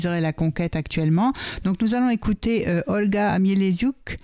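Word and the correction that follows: Mieleszczuk: Je prononce bien? – Non, mais des choux. – Mieleschouk, voilà. Olga Mieleschouk,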